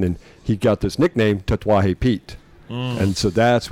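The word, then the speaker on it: Tatuaje: You know, some of it's just about where does the emphasis go, you know? So, yeah. But Tetuaje, we've got to put the emphasis on the right syllable.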